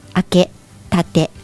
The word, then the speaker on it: 開け閉め: 襖に対して正面、真正面を向いて座るのが正式で一番丁寧な襖の開け立て、開け立て、開け閉めと書いて開け立てと言いますね。開け立てです。